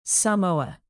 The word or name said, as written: samoa